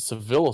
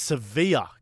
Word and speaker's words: Sevilla: Is it Seville or Sevilla? – It's Sevilla.